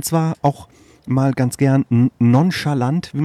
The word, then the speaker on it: nonchalant: Das Fremdwort nonchalant, habe ich das richtig ausgesprochen? – Nonchalant heißt das doch.